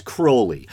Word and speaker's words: Crowley: The sex magic rituals inspired by Aleister, a lot of people say Crowley, I believe the proper pronunciation is Crowley, who founded the religion of Thelema, and laws involving emotional support animals.